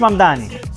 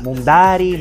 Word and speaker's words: Mamdani: Nadie sabe pronunciar mi apellido. Es Mamdani, pero a cada rato me dicen Mundari, Mumnari.